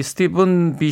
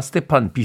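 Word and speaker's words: Stephen: S-T-E-P-H-E-N. 네. 예전에는 그냥 스티, 스티, 스티픈, 스티판? 스티픈, 스티픈 비숍. 이렇게 발음을 했습니다. 라디오 이렇게 듣다 보면, 어, 이 스티븐 비숍의 음악이 나올 때 많은 d j 들이다 스테판 비숍이라고 발음을 해서 저 혼자 스티븐이라고 하면 튀잖아요. 그러니까 저도 그냥 스테판 비숍이라고 부르도록 하겠습니다. 여러분들은 그렇게 부르지 마십시오.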